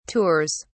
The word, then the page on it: tours: British English